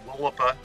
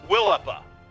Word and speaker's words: Willapa: Did I say that right? – Willapa Firearms Training. – Willapa. – Yep. – You know, I was putting in the emphasis on the wrong syllable, so that's what happens.